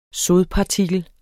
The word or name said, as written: sodpartikel